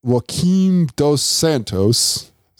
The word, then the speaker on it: Joaquin Dos Santos: And it was directed by one, and I'm going to butcher this name, Joaquin Dos Santos. Does that sound right to you? – Joaquin Sounds Dos good Santos?